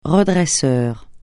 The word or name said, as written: redresseur